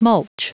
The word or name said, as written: mulch